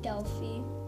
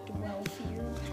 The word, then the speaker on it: Delphi: and then I learned that that I need to pronounce it like Delphi, not Dwelphi, however I pronounced it.